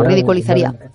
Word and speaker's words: ridiculizaría: A ver qué me sale, ridiculizaría ahora. – Exacto, ridiculizaría, sí. Es difícil de pronunciar. Piensa siempre en la palabra inicial, ridículo, entonces ridiculizaría. Divide, cuando una palabra se te revela, es mejor dividirla en dos.